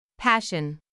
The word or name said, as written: passion